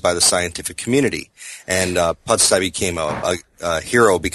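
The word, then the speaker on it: Pusztai: Is it Pustai? Did I say that correctly? – Pustai, yeah.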